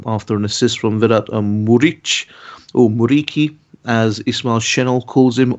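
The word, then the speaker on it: Muriqi: and which is, I think, is the correct way to pronounce it, actually. It's not Muric, it's Muriki, which is how the Kosovans say it.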